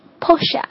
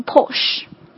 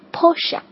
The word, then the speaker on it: Porsche: The next one，保时捷，大家很喜欢的一个牌子哈，正确发音是 Porsche。 Porsche，很多老外会发成 Porsche，也是错误的，应该正确发音是 Porsche。